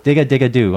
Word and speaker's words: Digga Digga Doo: Did I say that right? – Digga Digga Doo? – Digga Digga Doo. All right, so the G was awesome. – A little soft.